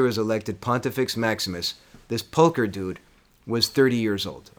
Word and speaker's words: Pulcher: P-U-L-C-H-E-R. What do you think? – Well, didn't we say that the C is a K? – Oh, Pulcher.